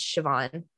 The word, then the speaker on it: Siobhan: I'm pretty sure it's pronounced Siobhan. It's the Irish where it has like weird consonants in the middle.